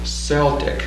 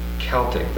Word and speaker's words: Celtic: Now I know we've all learned to say Celtic because of the Boston basketball team. But in Britain, they normally say Celtic. The C is a hard C.